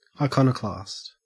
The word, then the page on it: iconoclast: Australian English